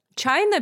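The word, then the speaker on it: China: Чайна пишется прям как Чайна.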